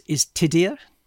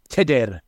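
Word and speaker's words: Tudur: So I notice you're pronouncing it Tudor. We English pronounce it Tudor, but the proper pronunciation you're saying is Tidir. – Tidir.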